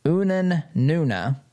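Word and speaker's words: Unanue: Goya Foods CEO Bob, and I believe it's pronounced Unanuna.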